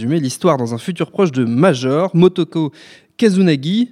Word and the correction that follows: Kusanagi: je le dis bien Daniel ? Pardon. – Kusanagi. – C'est ce que j'ai écrit en plus. Tu sais que c'est ce que j'ai écrit ? Ouais. J'ai dit Kazunagi, mais c'est Kusanagi qui a surmarqué.